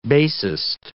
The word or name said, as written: bassist